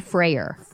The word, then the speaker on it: Freyer: F-R-E-Y-E-R. – Yeah, but it's pronounced like, you know, as what? If it was F-R-A-Y-E-R. – Right. I've always thought of it as like Friar Tuck. – Right. – But it's not.